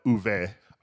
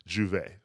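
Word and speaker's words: J'ouvert: The brand is called, in French, I would call it Uve, but the French patois pronunciation of Michael B. Jordan's rum brand is Juve, you know, which is a phrase borrowed from the start of Carnival in the Caribbean.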